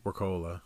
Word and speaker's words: Wirkola: Wirkola. – Spell it. – W-I-R-K-O-L-A. Wirkola. – You're adding extra letters in there. – W-I-R-K-O-L-A.